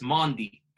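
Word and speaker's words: Mendy: I've taken the time to learn the correct pronunciation of my goalkeeper. It's Mandi. Because he's Mandi. French, right? – Oh, Mandi. – So man wow. like Mandi, yeah?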